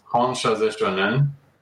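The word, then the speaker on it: Hon Sha Ze Sho Nen: It's pronounced Honshaze Shonen, and it's also known as the distance Reiki symbol.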